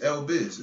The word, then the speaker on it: L Biz: And on social media, my name is L Biz. It's literally spelled like that. M-Y-N-A-M-E. However you spell my name and L-B-I-Z.